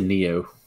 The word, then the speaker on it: NEEO: The brand is, how do you say that? N-E-E-O? Neo? Yeah. – Neo? Let's see if I can find it. – Yeah. – I say Neo.